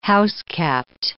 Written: housekept

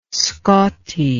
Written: scotti

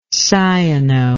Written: cyano

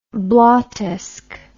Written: blottesque

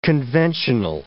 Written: conventional

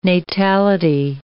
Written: natality

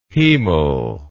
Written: hemal

